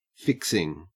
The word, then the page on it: fixing: Australian English